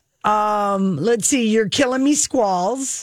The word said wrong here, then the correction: You're Killing Me Smalls: Oh, that's a reference to Sandlot. You're Killing Me Smalls.